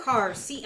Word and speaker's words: Karr: Carr. K-A-R-R.